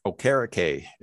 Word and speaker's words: Okereke: I'm going to have Leonard and Okekie as linebackers versus Derrick Henry. – Okereke is the new pronunciation.